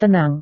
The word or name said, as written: tenang